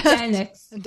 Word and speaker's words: Dernek: How do you mm-hmm. pronounce yeah, it? Dernek. That's correct.